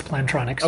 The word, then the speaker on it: Plantronics: Beyond that, I got to check out Plantronics. How would you say that? – I would say that that's Plantronics.